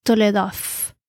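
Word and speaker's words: toledot: Generaciones eh, proviene de la palabra hebrea que se pronuncia Toledof.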